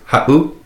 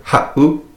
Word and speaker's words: ha'u: If you listen to that word ha'u, ha'u, It would be spelt H-A-7-U. If you were to take out that 7, it would be pronounced Hau. But with the 7 in there, makes that little glottal stop, that pause. So you say Hau, Hau.